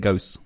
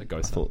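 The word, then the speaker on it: Gose: He was saying ghost. – Oh, yeah, no, I've been switching, I think. – Yeah. The Germans pronounce the E at the end of the word. Yeah. So Gosa.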